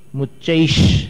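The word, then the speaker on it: मुच्चैश्: आपकी प्रतियों में क्या छपा है, हाँ, च के पहले श होगा, शक्कर वाला। नीचे की लाइन में ही चाहिए, सही है, उच्चारण ऊपर वाली लाइन में होगा, क्योंकि जैसे पहले बतलाया था ना कि स हो गया था केतोस, वैसे ही यहां पर मुच्चैश, ये श का उच्चारण यहां होगा।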